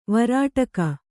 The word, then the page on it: ವೇರಡಿಗ: Kannada